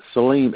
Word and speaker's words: Saleem: Salim, S-A-L-E-E-M is my name, but everybody calls me Salem.